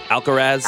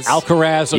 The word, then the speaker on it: Alcaraz: Alcaraz. – Alcaraz.